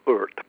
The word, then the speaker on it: Ordet: And his sound films, Vampire, Day of Wrath, and a film which most of us call Ordet, which is Danish for the word, but actually it's pronounced something like Urt.